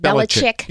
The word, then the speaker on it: Belichick: Belichick? How do you pronounce that? – Belichick. Yeah. – Belichick and his... – You got it.